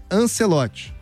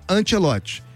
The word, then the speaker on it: Ancelotti: a pronúncia correta seria Ancelotti e não Ancelotti.